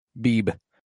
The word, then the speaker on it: Beebe: I feel bad because I definitely don't want to say it wrong, because if I do it, it's an easy one to say that sounds like a cartoon character. Paul Cameron and Dion Beebe. It's B-E-E-B-E. Beebe. – Dion Beebe. – Dion Beebe.